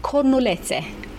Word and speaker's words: cornulețe: They are called cornulețe. – Cornulețe. – That's right. Okay. Pronunciation. Perfect.